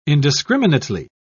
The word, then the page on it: indiscriminately: British English